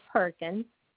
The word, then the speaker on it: Perkins: And then my last name is Perkins, PERKINS.